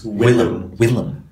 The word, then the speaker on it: Willem: But it, you said his name right. – Yeah, I'm proud of you. I did. No, I've been training myself to say it. – Do it. Yeah, lift up exercises. – Willem.